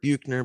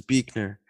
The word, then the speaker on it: Buechner: A lot of people call him Frederick Buechner, but I think the correct pronunciation is Buechner.